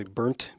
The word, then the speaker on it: Berndt: I think it's pronounced probably Burnt B E R N D T.